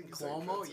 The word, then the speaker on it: Cuomo: Cuomo, yeah.